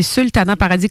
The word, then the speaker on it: Sultana Paradis: C'est ça, c'est Sultana Paradis, comme ça se prononce.